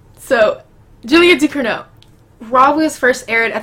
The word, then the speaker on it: Ducournau: It's spelled Ducournau. – Can I? – it's right there. – Oh, Ducournau. – Ducournau. – You said it right.